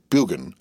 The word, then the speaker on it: Burgoyne: Nutting had a famous foxhound named Burgoyne. He pronounced it Bugan, which my informant used to borrow.